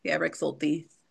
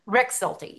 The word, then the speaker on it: Rexulti: What did you say it was? I don't want to say it wrong. – Rick Salty. – Rexulti. Yeah, I knew it. I know the commercials, so I knew I was saying it wrong.